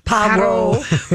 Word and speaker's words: Poirot: It again follows Hercule Poirot, and I spoke Poirot, or whatever you say his name is. – Poirot.